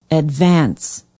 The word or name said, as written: advance